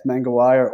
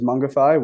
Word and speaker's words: Mangawai: The town, it's Mangawai, or it's some people will pronounce it as Mungify, which is the other native pronunciation.